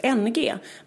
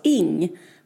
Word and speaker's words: Ng: I inslaget säger vi att författarens efternamn NG uttalas just NG, men det stavas bara så. Det uttalas Ing. Författaren vi pratar om heter alltså Celeste Ing.